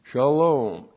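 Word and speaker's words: shalom (שלום): Shalom, S-H-A-L, long O-M, shalom.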